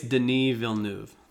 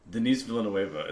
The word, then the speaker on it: Denis Villeneuve: But it's not that. It's because you don't pronounce any of it. – I think it's Denis Villeneuve.